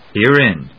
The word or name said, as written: Herein